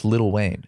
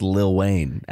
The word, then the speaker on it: Lil Wayne: It's pronounced Little Wayne. – It's pronounced Lil Wayne. – It's spelled L-I-L, but it's pronounced Little Wayne.